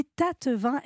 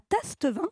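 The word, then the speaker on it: Tastevin: Attention, donc j'ai bien dit tas de vin et non pas tasse de vin.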